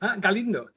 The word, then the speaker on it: Galindo: Galindo.